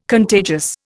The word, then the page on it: contagious: Indian